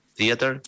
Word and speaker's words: Theater: No sé si lo digo bien, Vicky, ¿es Theater? – Sí, sí, está perfecto.